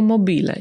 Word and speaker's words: mobile: Tu mamy słowo mobile w wymowie amerykańskiej, zapisywane jako mobile i pewnie najczęściej wymawiacie je jako mobile, no bo tak mówimy po brytyjsku.